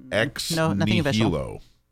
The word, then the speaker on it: ex nihilo: Do we have a ruling on how to pronounce that? Ex nihilo. – Yes, you did that very well.